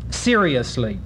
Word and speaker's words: seriously: The word spelled S-E-R-I-O-U-S-L-Y is a four-syllable word for most of us, seriously. However, Shakespeare expected it to be a three-syllable word, seriously, in the following line.